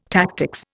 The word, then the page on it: tactics: Indian